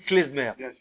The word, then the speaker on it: klezmer: Et donc après, comme en ashkenaz, on prononce vite, donc c'est devenu klezmer.